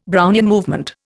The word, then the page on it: Brownian movement: Indian